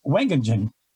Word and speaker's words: Wageningen: It was in a town called, if I can pronounce it correctly, because my Dutch is not very good, Wageningen, W-A-G-E-N-I-N-G-E-N, Wageningen.